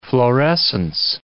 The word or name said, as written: florescence